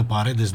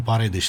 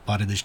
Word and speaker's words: Paredes: Și mai spune cineva că se pronunță Paredes, nu Paredes.